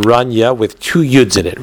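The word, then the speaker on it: Ranya (רניא): Because the way that he wrote the Resh Aleph Nun Yud Yud Aleph is usually written as Run, is usually pronounced as Ranya, with a chirik under the nun, an ethan under the nun, and a kamatz under the yud, Ranya. So if that's what he meant, then fine.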